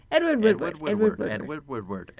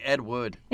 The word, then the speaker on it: Woodward: Edward Woodward. – I think I'm saying Ed Wood. Woodward. – You start to sound like a kid who can't pronounce R's. – I'm missing the R.